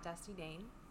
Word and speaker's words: Dusty Dayne: Okay, it's at Dusty Dayne.